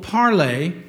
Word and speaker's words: Parler: spelled P-A-R-L-E-R, but it's French, it's pronounced Parler.